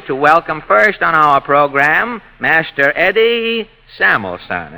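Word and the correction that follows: Salmanson: Sal Manson, huh? Yes, well, as long as all of the letters are in there, Eddie, we may as well pronounce them.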